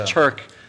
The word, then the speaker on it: Taerk: Pronounced Taerk, but spelled Taerk.